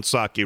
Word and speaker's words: Psaki: The P is silent. Saki, roll it.